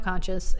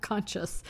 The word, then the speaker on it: conscience: He has no conscience and no guilt. I keep saying it wrong. Conscience, not conscious.